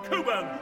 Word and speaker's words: Cockburn: And Coburn, not Cockburn, though for that you are excused. Tis spelt C-O-C-K, but only half the cock is used.